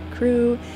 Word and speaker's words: CHRU: And WXYC would like you to know that the Carolina Harm Reduction Union, also known as CHRU, pronounced CREW, is a student-led program that provides life-saving harm reduction resources to the public of Chapel Hill.